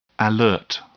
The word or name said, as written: alert